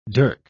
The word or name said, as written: dirk